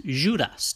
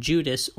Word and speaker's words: Judas: In German, it's Judas, spelled but not pronounced how we're used to seeing it. And then in French, it's Judas, taking that J sound for the first time.